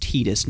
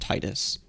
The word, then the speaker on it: Titus: I feel like that was something that down the line the voice actor or someone else confirmed that it's pronounced Titus, not Titus.